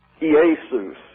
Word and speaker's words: Iesous: This is a long E here. I-E-S-O-U-S. Iesus.